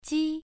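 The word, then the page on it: ji: tone 1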